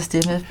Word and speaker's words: Steven: En aften, og det hvor Steffen, han havde Steven eller Steffen? – Steffen. – Det er Steffen. – Han staves Steven, men man udtaler Steffen.